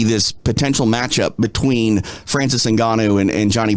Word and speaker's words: Ngannou: I'm going to say it wrong, but Ngannou? – Yeah, Francis Ngannou.